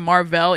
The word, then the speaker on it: Mar-Vell: It's annoying because they keep calling him Mar-Vell, even though it's spelled Marvel.